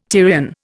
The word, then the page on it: Tyrian: Indian